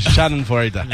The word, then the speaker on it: Schadenfreude: How do you say it? – Schadenfreude. – Yeah, right. Whatever.